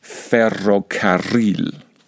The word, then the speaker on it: ferrocarril: I have to make a huge effort to say the word ferrocarril. Okay, so this word actually has two double R combinations.